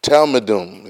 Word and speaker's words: Talmidim: I want to say it right. It's Talmidim. It's Talmidim.